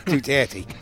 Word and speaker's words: Duterte: Okay, it's the president of the Philippines, Rodrigo Duterte. Rodrigo Duterte. He had an appointment, actually, in Liverpool this afternoon, but he didn't know what time it was. I had to tell him, it's Duterte.